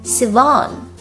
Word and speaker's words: Sivan: 后面 Sivan 重音在第二个音节读作 Sivan